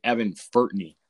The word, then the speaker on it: Furtney: Obviously, they lost their two best players from last year, at least their top two leading scorers, and Kyle Carey and looks like Evan Furtney. If I pronounce that right. – So, you did. Yep.